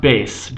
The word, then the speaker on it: Beit: A few differences in modern Hebrew dialects are in Ashkenazic Hebrew, Beit is usually pronounced base